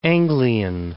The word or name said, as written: East Anglian